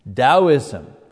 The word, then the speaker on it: Taoism: You know, you pronounce the T like a D. Taoism, that comes from China.